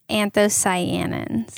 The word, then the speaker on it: anthocyanins: Is it cyanoids? What is it? – It's anthocyanins.